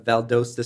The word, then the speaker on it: Valdosta: One of the other football related questions I had is as an undrafted free agent out of Valdosta State.